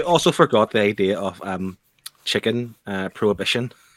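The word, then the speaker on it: Chickyn: And then chicken became a thing on the black market. – I pronounce that as chicken.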